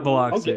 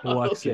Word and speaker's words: Biloxi: There were some Biloxi locals that were like, I hate when somebody mispronounces Biloxi. – Is it Biloxi? – Because I said Biloxi. – Am I saying it's not Biloxi. Okay. It's Biloxi.